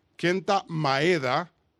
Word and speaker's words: Kenta Maeda: Y Kenta, quiero pronunciarlo bien, Kenta Maeda va a lanzar por los Dodgers contra los Gigantes mañana.